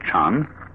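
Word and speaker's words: chan (禪): This word Zen is Japanese way of pronouncing the Chinese word chan, which in turn is the Chinese way of pronouncing the Sanskrit word jhana.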